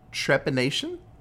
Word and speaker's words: trepanation: Trepanation? – Yeah.